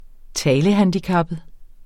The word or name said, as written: talehandicappet